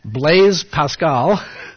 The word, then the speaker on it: Blaise Pascal: A French philosopher who lived in the 17th century, and I've been assured by Gabby Becker that the best way to pronounce his name for a non-French speaker like myself is Blaise Pascal, was trying to convince his friends to take the matter of the existence of God seriously.